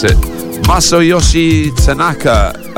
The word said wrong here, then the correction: Takanaka: Takanaka, I should have practiced that beforehand.